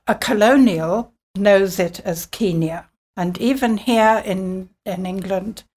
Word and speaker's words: Kenya: And that's where I was conditioned into saying Kenya, because that is how it's said now.